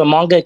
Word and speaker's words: Moonga: So Moonga K is my government Oh, it's Moonga K. – Yeah, it's two O's, but it's pronounced with one.